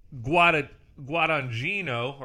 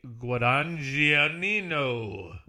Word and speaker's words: Guadagnino: How would you say that? There's some awkward lettering in there. – I would say Guadagnino.